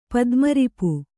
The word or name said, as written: ಪದ್ಮರಿಪು